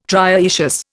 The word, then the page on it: trioecious: Indian